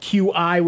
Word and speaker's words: Qi: The phones that have the charger is called QI, which is pronounced Qi.